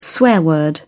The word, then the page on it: swear word: British English